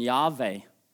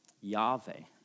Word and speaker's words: Yahweh: Sånn, i hebreisk, Yahweh, som vi uttaler hvordan vi tror det blir, sånn Yahweh.